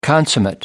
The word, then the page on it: consummate: American English